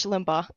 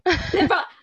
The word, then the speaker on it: Limbaugh: Limbaugh, I said it correctly? – Rush Limbaugh. – Limbaugh. I finally said his last name correctly. – You nailed it.